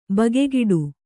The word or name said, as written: ಬಗೆಗಿಡು